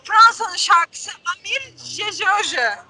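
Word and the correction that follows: J'ai cherché: Çok kısa şarkı nasıl telaffuz edildiğini söyleyeyim. Jeşerşe. Aradım Jeşerşe. diyor.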